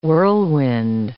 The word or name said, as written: whirlwind